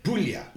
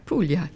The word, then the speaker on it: Puglia: And Puglia, is that how you say it? – Puglia. Yes. – Okay. – Yes. – You've got to put on the Italian accent, don't you? – Yeah, indeed, yeah.